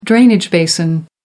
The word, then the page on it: drainage basin: American English